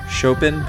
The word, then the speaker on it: Chopin: Anyway, it's spelled C-H-O-P-I-N, Chopin, but it's pronounced Chopin. I think I butchered that in the last episode. It's French. And disclaimer, I'm going to butcher some more French names in this episode.